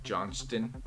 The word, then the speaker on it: Johnston: It's actually pronounced Johnston. – Johnston? That's fucking clapped.